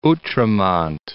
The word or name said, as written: Outremont